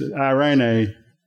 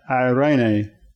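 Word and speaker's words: eirēnē: In the Greek, it's irene, E-I-R-E-N-E, and it's pronounced irene.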